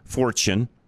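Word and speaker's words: Forstchen: It's it's F O R S T C H E N, but I think it's pronounced Fortune.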